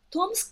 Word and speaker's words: Tomsk: Tomsk, but only 3 years.